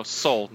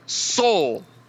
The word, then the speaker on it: Seoul: No, soul, not seal. What the fuck is wrong with you? It's soul. It's pronounced like soul.